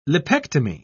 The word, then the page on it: lipectomy: British English